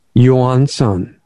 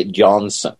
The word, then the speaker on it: Johansson: I'm trying to be really depressed when I say it, but Olle, is it Johansson? – Johansson. – Okay, the Americans don't know how to say it. They say Johansson. – Yeah. – It's kind of like... – Most people say Olle Johansson. – Johansson, that's even worse than I was Olle doing.